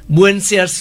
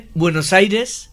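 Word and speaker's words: Buenos Aires: তার জন্য স্প্যানিশ ভাষাটা অনেক লজিক্যাল, যেরকম লিখছি সেরকম, শুধু কয়েকটা উচ্চারণের তফাত, বুয়েনসিয়ার্স নয় বুয়েনোস আইরেস, ইনফ্যাক্ট আর্জেন্টিনা তার ঠিক উচ্চারণ নয়, আর্জেন্টিনা, জিটা খ বা হ।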